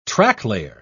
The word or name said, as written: tracklayer